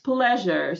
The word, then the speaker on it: pleasure: Здесь у нас pleased со звуком «и», а здесь pleasure со звуком «э».